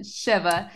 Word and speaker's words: Shiva: Shiva.